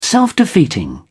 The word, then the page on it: self-defeating: British English